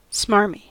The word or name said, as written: smarmy